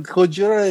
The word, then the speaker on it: Gojira: But Gojira is how he's pronounced in Japan.